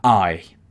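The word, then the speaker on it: eye: En lugar de eyes, solamente le quitas una S y queda I. Entonces, fíjate cómo I, aquí se repite otra vez, I y luego tiene otra cosa.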